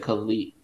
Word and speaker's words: Calle: I, have been listening to like interviews and stuff, and they said Kali, like uh.